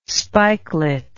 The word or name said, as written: spikelet